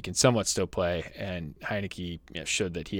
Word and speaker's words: Heinicke: And if you sort of wanted to go Taylor Heinicke, I think Heinicke is the right word, right pronunciation of his name, and Alex Smith is a stack later on.